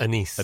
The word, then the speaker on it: anise: notes of anise. Is that the right pronunciation? – Anise. – Anise. Thank you.